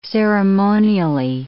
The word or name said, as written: ceremonially